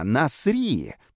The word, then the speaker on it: Насирия: «А, Насри!» — произнес тот с ударением на последний слог, словно призывая к действию.